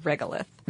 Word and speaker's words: regolith: So the dust that covers Mars is called regolith. – Mm-hmm. – If I am pronouncing that correctly. – You are.